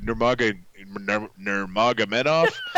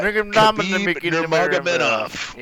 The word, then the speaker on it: Nurmagomedov: But my buttery biscuit player is from the UFC card, and it's Khabib Nurmagomedov. – Try that again. – Yeah. Nurmagomedov.